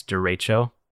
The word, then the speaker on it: derecho: Also, real quick, I want to correct my pronunciation. It's derecho. – Is that like derecho.